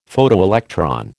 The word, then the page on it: Photoelectron: American English